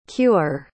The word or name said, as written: queuer